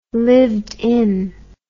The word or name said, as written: lived in